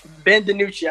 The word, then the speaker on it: DiNucci: Ben DiNucci.